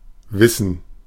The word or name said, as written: wissen